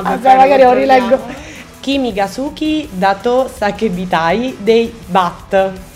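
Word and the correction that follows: BAAD: Bad. Dei Bad. L'ho detto bene, vero?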